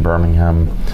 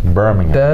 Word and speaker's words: Birmingham: I was in London, Birmingham. Birmingham, is that the correct pronunciation? – Birmingham. I think if you're there, it's Birmingham, but I call it Birmingham.